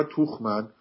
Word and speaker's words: Tuchman: حالا TUCHMAN ما با آلمانی میگیم توخمن چون توخ میشه دستمال، میگن دستمال چی داریم ما، دیدی در یارش دستمال چیه؟ – آره.